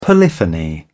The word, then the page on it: polyphony: British English